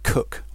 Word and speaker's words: Coke: It's spelt like Coke, but it's pronounced Cook. That's a Norfolk thing.